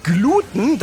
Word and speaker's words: Gluten: Und mir wurde noch gesagt, dass man das Wort auch oft auf dem U betont, weil Gluten das lateinische Wort für Leim ist. Hm. Und aus dem Lateinischen ins Deutsche übertragene Wörter haben häufig ihre Betonung auf dem langen Vokal. Aber im Prinzip ist beides richtig, je nachdem, wie man es mal gelernt hat.